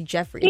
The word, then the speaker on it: Joffrey: They spell it Joffrey, though, where they pronounce it Jeffrey.